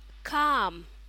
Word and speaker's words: calm: Okay, guys, so another example of a collocation is keep calm, cálmate, relájate, quedarse tranquilo, keep calm. That's how it's pronounced. We don't pronounce the L, the L is silent, the letter L.